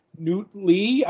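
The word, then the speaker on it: Nhut Le: And also Newt Lee, that's how you say his name, Judo Master.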